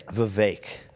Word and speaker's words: Vivek: Vivek rhymes with cake. – I do not hear the difference that you're saying. – Vivek. – You're saying Vivek. – Okay. – It's Vivek.